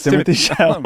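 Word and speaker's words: Timothée Chalamet: Yeah, it's Timothy Chalamet.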